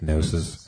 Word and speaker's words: Gnosis: I always say Gnosis, but I'm pretty sure it's Gnosis. It has a silent G. The G is silent.